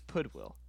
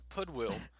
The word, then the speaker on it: Pudwill: Wait, how did you pronounce it earlier? – Pod Will. Because it's like Goodwill. – So it's with a P. – Pudwill. – It's Pudwill. – Okay, so Pudwill is a play on words about podcast and Goodwill, which is pretty clever.